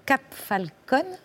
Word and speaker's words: Cap Falcon: En 1962, vous fuyez au rang pour vous installer au Cap Falcon, je le prononce bien.